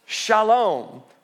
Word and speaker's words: shalom: Now, this word peace in Hebrew is a rich and profound word pronounced shalom.